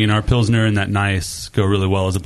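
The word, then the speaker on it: Gnies: Yeah, it's like G-N-I-E-S-S. It's pronounced Nice.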